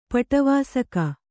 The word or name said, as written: ಪಟವಾಸಕ